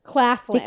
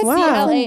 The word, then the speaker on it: Claflin: Claflin. – Victoria Claflin. C-L-A-F-L-I-N.